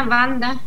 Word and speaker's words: Wanda: Bueno, vivo en Colonia Banda, se dice Banda porque fueron los polacos que fundaron esta, pero se escribe Wanda.